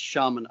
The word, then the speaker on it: Sharman: And then if they are interested in coaching, it's Sharman Ultra. And yes, that is like the toilet paper, but it's not spelled like that.